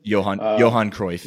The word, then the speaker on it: Johan Cruyff: The stadium that Ajax plays in, the John Cruyff, I believe I'm saying that right, stadium. – Johan Cruyff. – Yeah, yep.